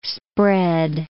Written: spread

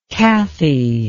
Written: kathy

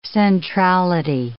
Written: centrality

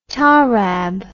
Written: tarab

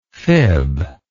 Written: fibster

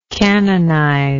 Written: canonizer

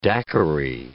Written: daiquiri

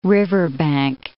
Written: riverbank

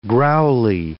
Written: growly

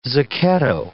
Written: zucchetto

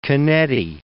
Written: Canetti

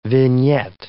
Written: vignette

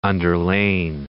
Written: underlain

